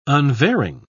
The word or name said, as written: unvarying